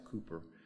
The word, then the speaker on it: Cowper: The writer of this song, William Cowper, it looks like Cowper, but they pronounce it Cooper, dealt with that most of his life.